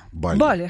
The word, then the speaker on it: Бали: Бали или Бали, говорить правильно? – Бали.